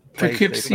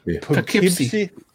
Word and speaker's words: Poughkeepsie: Poughkeepsie, like, fucking impossible. – Poughkeepsie. Yeah, okay. There you go. – So Poughkeepsie.